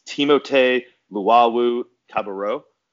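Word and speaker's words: Timothé Luwawu-Cabarrot: It's Timotei Luwawu-Cabarrot.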